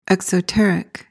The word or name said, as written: exoteric